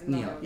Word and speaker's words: NIOD: Neod. – Right. – Did I say it right? – It's Neod. Yep.